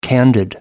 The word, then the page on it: candid: American English